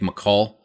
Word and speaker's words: Makhoul: I believe it's Nick McCall. Nick M-A-K-H-O-U-L. What do you got for me there? You're the smart guy of this tandem. – I mean, that sounds about right.